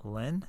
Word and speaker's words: Lynne: Did I mispronounce Jeff's last name? – No, Lynne.